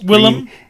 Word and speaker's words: Willem: Hey, apparently William Dafoe, Last Temptation of Christ. – Willem.